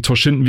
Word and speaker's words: Toshinden: Und in der blauen Ecke der neue Herausforderer aus Japan, Toshinden. Habe ich das hier ausgesprochen? – Toshinden, Toshinden, ja. Toshinden, ja.